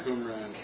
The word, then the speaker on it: Qumran: Qumran.